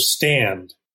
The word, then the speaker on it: Standd: You're the founder of Stand, if I'm pronouncing that correctly. – That's right. The second D is silent.